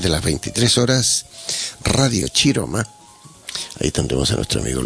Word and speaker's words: Chiroma: Y Chiroma se escribe con C, se escribe Chiroma, pero en italiano es Chiroma.